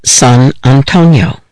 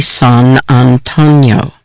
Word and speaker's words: San Antonio: This was the summer of 1979, and most of us living on the border called the city San Antonio, as it's pronounced in Spanish, and not San Antonio, as I'd heard it pronounced in other places.